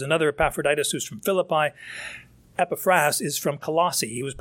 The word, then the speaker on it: Epaphras: Verse 12 talks about this man Epaphras. The emphasis ought to be on the last syllable, but if I mistake it, you'll understand who I'm talking about.